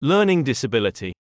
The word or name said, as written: learning disability